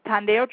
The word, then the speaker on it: Tondeo: Tondeo is spelled T-O-N-D-E-O. It starts with a T as in Thomas. Tondeo Traders, T-R-A-D-E-R-S.